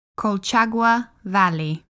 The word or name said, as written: Colchagua Valley